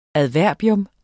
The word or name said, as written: adverbium